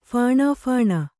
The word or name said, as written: ಫಾಣಾಫಾಣ